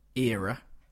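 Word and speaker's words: Eera: And the song is Drive With Fear from, I think it's pronounced Era. It's E-E-R-A. Double E-R-A.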